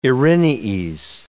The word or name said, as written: Erinyes